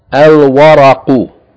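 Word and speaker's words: Al-waraqu (الورق): Al-waraqu, al-waraqu, meaning the paper or paper. Again, the first word, the first letter is waw, it's not a sun letter, there's no doubling here, the lam should be pronounced here.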